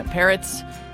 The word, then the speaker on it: Parrots: Thanks to our video producer, Ben Hethcote, and our video intern, Ethan Parrots. And I love that the pronouncer for Ethan is always like, parrots, like the bird.